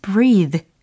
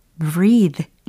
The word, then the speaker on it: breathe: can't 뒤에 breathe 라고 발음하셔야 되는 철자였습니다. b-r-e-a-t-h-e e. 동사 적인 역할이었죠. 명사는 뺑 끝에 있는 철자 e가 떨어져 나가고 어, 모음 부분이 breath 이렇게 발음이 되는 거죠. 근데 동사적으로 쓰인다면 철자 e로 끝나면서 breathe 예, 중간의 모음도 바뀌게 됩니다.